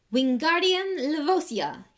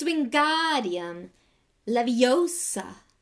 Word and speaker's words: Wingardium Leviosa: Wingardium Levosia, he shouted, waving his long arms like a windmill. You're saying it wrong, Harry heard Hermione snap. It's Wingardium Leviosa. Make the gar nice and long.